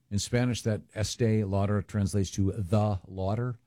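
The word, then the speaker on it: Estée Lauder: Is it pronounced Estee Lauder? Estee Lauder, yes.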